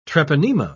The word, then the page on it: treponema: British English